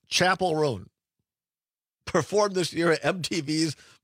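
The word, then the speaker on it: Chappell Roan: I would have also guessed Chappelle based on how it's spelled.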